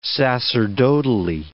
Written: sacerdotally

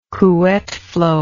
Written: Couette flow

